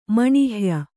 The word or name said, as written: ಮಣಿಹ್ಯ